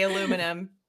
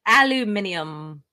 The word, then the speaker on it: aluminium: I do not say aluminum. – Aluminium. – Aluminium. And yes, we spell it aluminium.